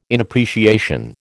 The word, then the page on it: inappreciation: American English